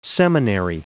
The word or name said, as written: seminary